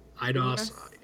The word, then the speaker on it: Eidos: Eidos.